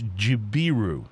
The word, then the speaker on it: Jibiru: It is, I believe, called Jibru. I'm wrong already. It's Jibiru. – Jibiru? – J-I-B-I-R-U.